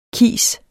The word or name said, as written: kis